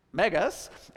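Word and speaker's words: megas (μέγας): So the top word is how it looks in lowercase Greek, megas. It's pronounced megas, and it means large, great, powerful, extraordinary.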